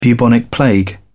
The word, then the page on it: bubonic plague: British English